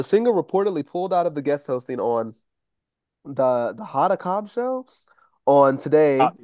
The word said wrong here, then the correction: Hoda Kotb: The B is a letter and it's literally pronounced Kotb.